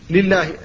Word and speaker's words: لله: ثم لام لله لنا كذلك لا بد من الحذر من تفخيم لام لله، الحمد لله، لا تقول الحمد لله كما يفعل بعض الناس،